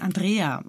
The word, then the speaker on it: Andrea: Und dann habe ich gesagt, na ja, ich bin Deutsche, eigentlich spricht man meinen Namen anders aus, ich bin Andrea.